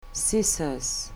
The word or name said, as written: scissors